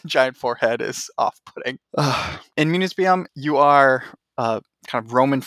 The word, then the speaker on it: Municipium: And then we're going to jump to 2008 with Municipium. Did I say that right? – Municipium, yes. – Yes.